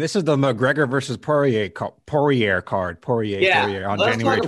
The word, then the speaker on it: Poirier: Poirier. I'm pretty sure it's Poirier. I mean, that's how they say it on all the UFC programs. – All right, Poirier.